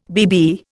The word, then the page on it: BB: Indian